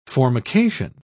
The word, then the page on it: formication: British English